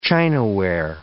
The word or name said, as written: chinaware